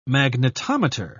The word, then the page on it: magnetometer: British English